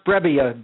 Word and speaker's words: Brebbia: And the name is pronounced Brebbia, B-R-E-B-B-I-A, as you know.